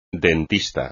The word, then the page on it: dentista: espagnol